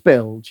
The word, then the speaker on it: Bilge: B-I-L-G-E.